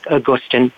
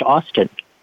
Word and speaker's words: Augustin: It does look like Augustin, but it's actually pronounced Austin.